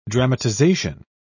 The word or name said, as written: dramatization